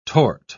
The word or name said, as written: torte